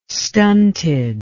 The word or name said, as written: stunted